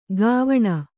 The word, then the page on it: ಕ್ವಣ: kannada